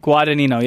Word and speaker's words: Guadagnino: Vinny Guadagnino. – Yeah. – Did I say it right? – Guadagnino, yeah. – God damn it. Guadagnino.